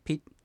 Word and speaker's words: ピッ: はい、そうですね、その重り石ってなってるピッですね、小石とこの単語のところでは訳してますけど、これがですね、まあ普通は日本語にないような発音ですから、カタカナで書いてるやつはピン、小さい、ツーと書いてる。これピッ、フン、アヌ、口を閉じて終わるピの音っていうのが、ね、あるって感じです。